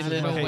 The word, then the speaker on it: Lil Wayne: It's Lil Wayne. – Lil. – Lil. – Lil. – Oh, Lil. – There's no T.